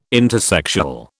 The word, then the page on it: intersexual: British English